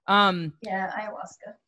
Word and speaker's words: Ayahuasca: She's a facilitator and a yoga teacher at, it's Ayahuasca, right? I always say it wrong. – Ayahuasca. – Ayahuasca.